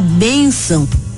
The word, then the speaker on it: bênção: A palavra bênção é paroxítona porque tem a penúltima sílaba tônica. A sílaba bem é pronunciada mais forte do que a sílaba são, porque é paroxítona.